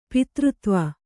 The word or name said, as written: ಪಿತೃತ್ವ